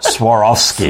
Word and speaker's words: Swarovski: It sounds like you're stumbling over it, but that Swarovski. – Swarovski. – It does, but that's actually the correct pronunciation.